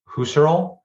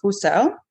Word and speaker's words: Husserl: And I want to applaud you for your German pronunciation of Husserl, because there's a variety of different ways you could have gone about that last name, and you chose the correct way.